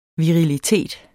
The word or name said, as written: virilitet